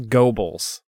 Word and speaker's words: Goebbels: And it says Goebbels. – Goebbels? – Yeah, it's pronounced G-O-W-B-L-Z.